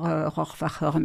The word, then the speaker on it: Rohrwacher: À l'allemande, on doit dire Rohrwacher, mais bon, peu importe.